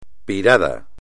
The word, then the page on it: pirado: espagnol